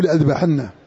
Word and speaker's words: لأذبحنه: وكثير غيرها، لو عذبنه عذابا شديدا أو لأذبحنه، في المصحف تجدها كيف مكتوبة، لا أعذبنه ولا أذبحنه، تقرأ هكذا فأنت قد حرفت كتاب الله، فأنت قد حرفت كتاب الله، نقضت الكلام. هذا الحرف لا ينطق،